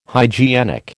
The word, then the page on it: hygienic: American English